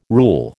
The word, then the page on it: rule: American English